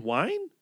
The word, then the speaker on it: Wein: I took 4 years of German, and I think the second vowel is what you pronounce. So I guess it's Len Wein.